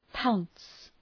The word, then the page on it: pounce: paʋns